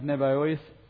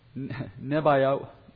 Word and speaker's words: Nebaioth: The rams of Nebaioth, Nebaioth, I think I said that right. Nebaioth will minister to you.